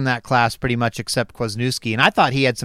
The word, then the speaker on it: Kwasniewski: Kwasniewski.